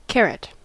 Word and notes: porkkana: /ˈpor.kːɑ.nɑ/

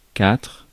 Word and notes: quatre: France

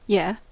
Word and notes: kyllä: /ky.lːæ/

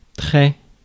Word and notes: très: France; /tʁɛ/

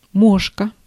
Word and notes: мошка: /ˈmoʂkə/; moška

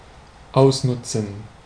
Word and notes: saisir: France (Paris); [se.ziʁ]; /sɛ.ziʀ/; /sɛ.ziʁ/

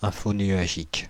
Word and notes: infonuagique: France (Île-de-France); [ɛ̃.fɔ.nɥa.ʒik]

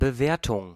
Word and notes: estimation: /ɛs.ti.ma.sjɔ̃/

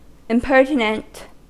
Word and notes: hardi: French

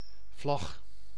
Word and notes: flag: /flæɡ/

